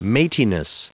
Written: mateyness

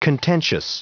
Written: contentious

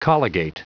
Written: colligate

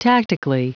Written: tactically

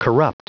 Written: corrupt

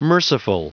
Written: merciful

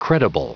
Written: credible

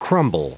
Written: crumble

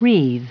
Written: wreathe

